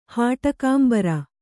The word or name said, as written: ಹಾಟಕಾಂಬರ